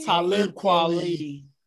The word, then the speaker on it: Talib Kweli: Talib Kweli.